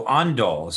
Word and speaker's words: Ondol: So, are we saying it right? – Yeah, you're saying it correctly. So, Ondol.